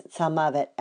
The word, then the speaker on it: tzalmavet: But in our pasuk, there's a variation on the pronunciation, and we pronounce it as tzalmavet as opposed to tzalmut.